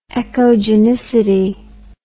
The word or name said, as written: echogenicity